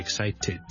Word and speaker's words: excited: 我感到非常之興奮，I'm really excited。最尾要 ted 之後翻出嚟咧，我先至一個完整嘅發音嚟嘅。